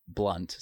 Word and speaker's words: Blount: It's spelled Blount, but it's pronounced Blunt.